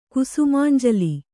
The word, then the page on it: ಕುಸುಮಾಂಜಲಿ: Kannada; kusumānjali